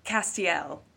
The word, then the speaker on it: Castiel: How do you say his name? – Castiel. – Ah!